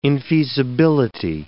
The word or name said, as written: infeasibility